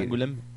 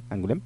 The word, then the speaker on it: Angoulême: Yo te digo que me imagino que a lo mejor sea una onda tipo el Festival Cervantino aquí, pero hay en Angoulême, a lo mejor no se pronuncia así. – Angoulême. – ¿Sí? Sí. Angoulême, okay.